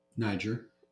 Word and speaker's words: Niger: Niger.